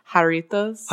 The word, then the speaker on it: Jarritos: How do you pronounce? – Oh no, you're putting me on the spot. We just listened to this. It's Jarritos.